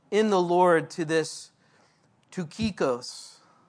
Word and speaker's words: Tychicus: So in verse 7, Tychicus, don't laugh. I actually looked that one up. And I bet most of you wouldn't look at that word and say it like that. Tychicus, a beloved brother.